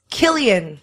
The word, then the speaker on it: Cillian: A lot of people wrote in, pronounce Killian.